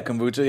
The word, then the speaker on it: kombucha: how do you pronounce it, kombucha? – Yeah, kombucha, yeah.